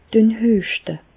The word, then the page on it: Düen hüeschte: Bas Rhin; Reichshoffen